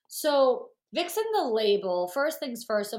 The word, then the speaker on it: Vicsen: Vixen, like Vixen. Victoria, and like Vixen being sexy, like okay. V-I-X-E-N. So this is Vic, V-I-C-S-E-N. Vixen. – Okay, you literally have a graphic explaining this, and I was still struggling. So thank you. – Yeah, no, it's Vixen, like how we've always heard, like what a Vixen is. So it's that, but it's spelled Vic, like ooh, Victoria.